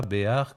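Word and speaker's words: Béhar: Alors Guy Béard s'appelle vraiment Guy Béard, même si son vrai nom ne s'écrit pas Béard comme on l'écrit d'habitude pour lui, mais bien B-E-H-A-R, Béard.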